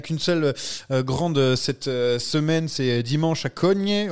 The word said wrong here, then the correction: Cogne: on prononce comme ça, Jérémy Ou Cogne non, non, Cogné.